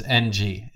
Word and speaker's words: Ng: And that's N-G. It's, you pronounce it Ng.